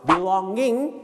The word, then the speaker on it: belonging: ก็คือหมามันกัดกินการบ้านผมไปหมดแล้วครับคุณครูซึ่งก็คือข้ออ้างของการที่บอกว่าไม่ได้ทำนั่นเองครับต่อไปก็คือ belongs นั่นเองครับนะฮะ belongs it belongs to me it belongs to you นะฮะ belonging แปลว่าของมีค่าอย่าลืมออกเสียงงิ้งนะครับ